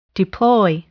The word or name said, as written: deploy